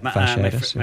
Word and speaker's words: fasciitis: Is that how it's pronounced? – Yeah, fasciitis.